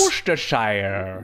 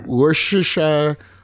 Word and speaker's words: Worcestershire: Worcestershire. – Worcestershire.